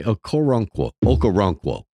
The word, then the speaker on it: Okoronkwo: Cleveland's Ogbo Okorowunkwo broke. Through the, am I saying that right? Okoronkwo broke through the line and sacked Burrow.